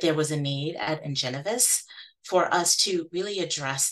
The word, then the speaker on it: Ingenovis: Ingenovis.